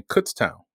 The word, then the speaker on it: Kutztown: And I'm saying it that way because that's how it's pronounced. But when you look at the doggone word, it's spelled K-U-T-Z town, right? So easily you're going to say Kutztown. So I spent all week saying, all right, it's Kutztown.